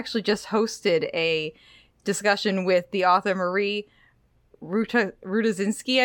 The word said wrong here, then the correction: Rutkowski: I believe is how you pronounce her last Rutkowski. – Name. Rutkowski. Thank you, Jeff.